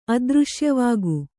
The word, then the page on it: ಅದೃಶ್ಯವಾಗು: kannada